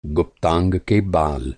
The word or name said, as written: गुप्तांग